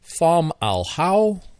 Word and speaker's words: Fomalhaut: But it's called Fomalhaut or Fomalhaut. I hear people pronouncing Fomalhaut. – Fomalhaut, Fomalhaut, Fomalhaut. Basically, it's three words. Fom, al, and hot, or hal.